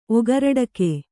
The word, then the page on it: ಒಗರಡಕೆ: Kannada